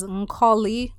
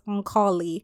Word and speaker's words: Nkoli: Nkoli is pronounced like the latter part of the word melancholy, starting with the N. So it's Nkoli. It's quite hard to pronounce for some people, but if you just think of it that way, it's pretty easy.